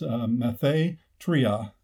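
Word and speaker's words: mathetria (μαθήτρια): And I may butcher the Greek, but it's mathetria, that is M-A-T-H-E with a long E, T-R-I-A.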